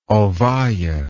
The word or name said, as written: ovalle